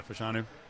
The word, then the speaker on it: Olu Fashanu: If I'm getting the pronunciation right, Olu Fashanu. – Yeah, Fashanu.